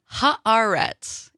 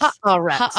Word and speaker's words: Haaretz: I'll correct that pronunciation. Sorry. – Tell me. – I wanted to tell you when Dom was on, Haaretz, if that helps. – Haaretz.